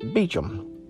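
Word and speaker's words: Beauchamp: It's a hard one to pronounce the way it's spelled, I tell you. It's pronounced Beecham.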